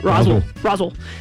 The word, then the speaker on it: Roswell: Somebody told me that's how you say it. Roswell.